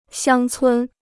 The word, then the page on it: 乡村: xiāng cūn